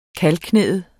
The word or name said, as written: kalveknæet